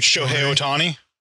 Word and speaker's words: Shohei Ohtani: I can never say his name right. – Shohei Ohtani. – So Shohei Ohtani. – There you go.